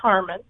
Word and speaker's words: Heuerman: His name is spelled H-E-U-E-R-M-A-N, but it is pronounced Harmon.